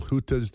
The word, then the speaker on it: hytte: big ones, little hutas that they, it's spelled like H-Y-T-T-E, but I think it's pronounced huta.